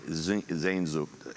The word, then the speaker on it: Sehnsucht: It doesn't actually spell out Sehnsucht. It's an entirely different spelling. So, but anyway, trust me, that's the way it's pronounced.